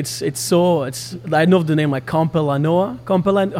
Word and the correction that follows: Campoleone: How do you say it? – Campoleone. – Campoleone. Jesus, that was Campoleone, very far from the right the right thing.